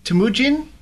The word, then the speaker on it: Temujin: Is he Temujin? – Temujin, I think it is, yeah. It's a proper Mongolian word, I think.